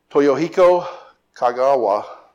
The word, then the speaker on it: Toyohiko Kagawa: Toyohiko Kagawa. That was perfect, by the way.